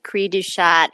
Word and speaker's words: cri du chat: Is it cri du chat? I never know how to pronounce it properly. – Yes, you said it perfectly. – Okay, cri du chat.